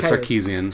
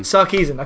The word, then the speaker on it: Sarkeesian: Yeah, it's Trotes versus Women in Video Games, the Anita Sarkeesian. If I said that right, I always pronounce that name wrong. I forget what Anita Sarkeesian. – Sarkeesian. I put the K too early.